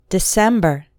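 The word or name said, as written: December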